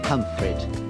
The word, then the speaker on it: Pontefract: And this is a description of the licorice fields at Pontefract, which may or may not be pronounced Pumfret, in Yorkshire.